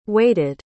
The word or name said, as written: waited